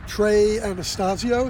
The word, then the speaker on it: Trey Anastasio: Yeah, I picked a song called Blaze On by Trey Anastasio.